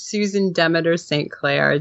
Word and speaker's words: Susan Demeter-St. Clair: Demeter. It's Susan Demeter-St. Clair. Demeter as like the goddess. – The Greek goddess. Yeah, yes. But it's okay because everybody makes that mistake.